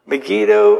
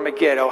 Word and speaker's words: Megiddo: Megiddo or Megiddo, however you want to pronounce it, either way is fine.